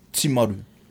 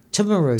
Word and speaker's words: Timaru: It's like similar to the T, but it's the I is always like E. Timaru. – Timaru. – Timaru. – Timaru. – See my roof.